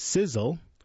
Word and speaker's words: SZL: Called SZL, pronounced Sizzle, but spelled SZL,